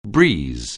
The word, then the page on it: breeze: anglais